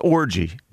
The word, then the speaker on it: Orji: Or maybe a soft J, like yogging or Orgy or whatever. – All right. – But his name is pronounced Orgy. – We call him O. – That's unbelievable. – O-R-J-I.